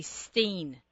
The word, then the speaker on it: Stein: But in Australia, we say Steen. S-T-E-I-N is pronounced Steen, so I never know.